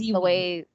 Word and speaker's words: Zee Oui: Is that how we're pronouncing it? Zee Oui? Like that's Zee Oui. It rhymes with Kiwi.